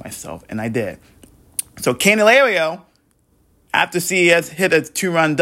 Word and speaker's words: Candelario: And then Candelario, I finally got that name right.